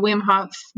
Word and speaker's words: Wim Hof: So you do the Wim Hof method.